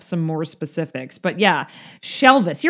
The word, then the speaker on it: Shelvis: This Elvis is actually Shelvis. – And are we saying that right, or is it She Elvis? – Well, maybe She Elvis, but I think I like Shelvis.